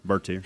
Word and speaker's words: Bertier: You took the Gary Bertier, is that how you say his name? – Yeah, Bertier.